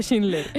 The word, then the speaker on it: Schindler: De Schindler.